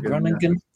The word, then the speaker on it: Groningen: FC Groningen, yeah.